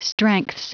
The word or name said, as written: strengths